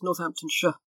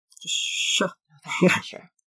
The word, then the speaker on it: Northamptonshire: No, with our place names, we tend to swallow most of it. So it's Northamptonshire. – Northamptonshire.